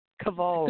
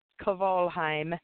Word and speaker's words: Kvalheim: Kvalheim. – Good Norwegian name. – Kvalheim.